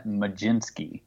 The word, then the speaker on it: Mlodzinski: It's Carmen Mlodzinski. – You're kidding me. no, I ran that by multiple people just to make sure. Yeah, Carmen Mlodzinski.